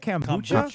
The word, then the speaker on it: kombucha: Kombucha.